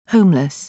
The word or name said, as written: homeless